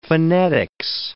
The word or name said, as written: phonetics